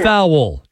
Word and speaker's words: foul: The word foul pronounced with two syllables. It's one syllable.